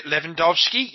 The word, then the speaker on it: Lewandowski: Lewandowski.